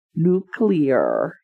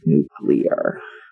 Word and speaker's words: nuclear: It's actually pronounced nuclear, not nuclear, which is how they pronounced it every time they said it in this movie. Specifically, Michael Douglas kept saying nuclear. I'm like, uh, that's not right. That's how Homer Simpson says it.